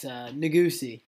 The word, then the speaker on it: Negussie: It's Negussie. N-E-G-U-S-S-I-E, that's how you say it.